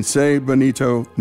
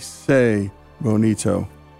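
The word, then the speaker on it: Tse Bonito: To find out more about the museum, Google Navajo Code Talkers Museum in Se Bonito, New Mexico. It's spelled T-S-E, Se Bonito.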